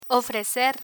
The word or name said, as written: ofrecer